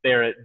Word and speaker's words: Barratt: It's I-V-A-N, Ivan Barrett, B-A-R-R-A-T-T.